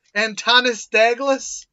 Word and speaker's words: Antonis Daglis: Please tell me you wrote his name wrong, or I'm mispronouncing it. Antonis Daglis? – No, that's right.